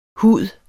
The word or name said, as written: HUDERNES